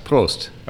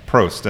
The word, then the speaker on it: Prost: Right now, let's say this right. Prost? – Prost. – Okay. Yes. Prost.